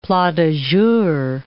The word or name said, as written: plat du jour